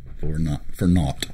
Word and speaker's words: naught: We just want to know that you're there, and we're here, and that this is not all for naught. Did I say that correctly? – N-A-U-G-H-T, yes. – Okay.